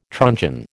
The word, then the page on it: truncheon: American English